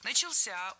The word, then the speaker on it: начался: Начался — ударение на возвратную часть «ся».